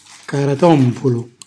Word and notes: caratompulu: calabrese

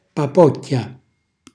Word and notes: papocchia: calabrese

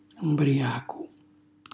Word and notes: mbriacu: Calabrese

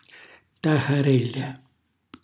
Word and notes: taxarella: Calabrese

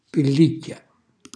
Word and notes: pellicchia: calabrese

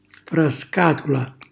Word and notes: frascatula: calabrese

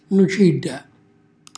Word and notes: nucilla: calabrese

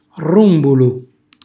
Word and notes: rrumbulu: Calabrese

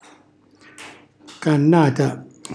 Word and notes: cannata: calabrese

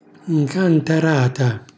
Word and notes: ncantarata: Calabrese

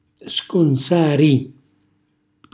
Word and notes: sconsari: Calabrese

